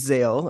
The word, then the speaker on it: Zale: Yeah, so my name's Zale, as you know, and I mean, in theory, I would think that's not a hard name to pronounce, but it's rare, so it is, and people have, like, said Zero, Zalo. Yeah, people struggle.